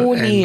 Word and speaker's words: No Name: Also no Name. – N-O und der Name wird auf Englisch Name ausgesprochen.